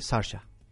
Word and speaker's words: Saoirse: Sí, que era la niña pequeña también, sí, que estuvo nominada mejor se de se, se escribe Saoirse, pero se dice Sasha.